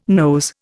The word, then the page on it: nose: Indian